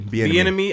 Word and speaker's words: Bieniemy: I definitely expected Eric, how do you pronounce his last name? – I think Bieniemy. – Bieniemy.